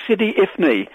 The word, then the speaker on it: Sidi Ifni: I can't remember how to spell it, Ian, but it's Sidi Ifni.